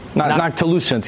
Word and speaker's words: noctilucent: Non-ticulant cloud, right? – Noctilucent? – Non-ticulant cloud? Noctilucent. Yeah, yeah. I've mispronounced it like 1,000 times.